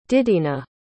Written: didina

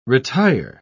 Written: retire

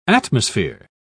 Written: atmosphere

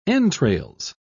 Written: entrails

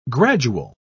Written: gradual

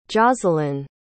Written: josalyn

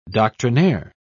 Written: doctrinaire

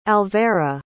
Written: alvera

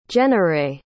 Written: jennarae

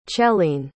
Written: celene

